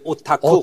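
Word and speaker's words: 오타쿠 (おたく): 할때 귀댁이라는 단어의 일본식 발음이래요. 그게 오타쿠. 오타쿠.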